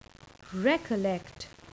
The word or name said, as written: recollect